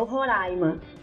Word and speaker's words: Roraima: Eu cheguei lá falando Roraima e o pessoal não tem acento. – Falei, beleza, como é que é? O Roraima. Agora eu tô falando certo.